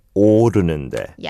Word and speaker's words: 오르는데: I found the 오르는데, the 오 vowel sounded a bit like 어. – 오, 오르는데. – Yeah. – 열정은 오르는데. – Yeah, Teto pronounce a bit like 오르는데. – 오르는데. – Yeah, but it's 오.